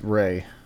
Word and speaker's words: Raye: My last name is Ray. R-A-Y. – It's Ray? – R-A-Y-E. Like Ray-E, but it's just pronounced Ray.